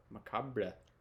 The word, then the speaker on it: macabre: It's a very like dark and macabre. Macabre. Am I saying that right? – It's just macabre. – Macabre. – There's no, you don't have to say the R-E on the end.